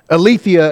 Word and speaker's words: Aletheia: Aletheia is how you would pronounce that.